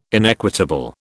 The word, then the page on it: inequitable: British English